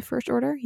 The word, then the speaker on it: First Order: waar we de First Order, zeg ik het goed? De First Order, ja.